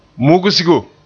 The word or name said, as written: mugsigo